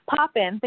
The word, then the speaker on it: Poppin: Poppin. Think of Mary Poppins. Just drop the S.